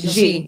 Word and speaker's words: Xi: Oh, Xi.